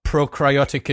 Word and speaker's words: prokaryotic: You didn't think I knew about prokaryotic and I can't even pronounce it. Those two. – Prokaryotic. You got it.